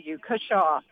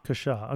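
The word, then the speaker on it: cushaw: I think it's C-U-S-H-A-W, Kershaw. – Kershaw, okay.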